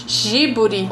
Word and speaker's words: Ghibli: E o nome, né, eu falo Ghibli, mas é, eu acho que a pronúncia correta é G giburi ou giburi,